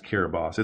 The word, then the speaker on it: Kiribati: But it's really the tiny Republic of Kiribati, spelled Kiribati, but pronounced Kiribati.